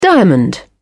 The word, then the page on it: diamond: British English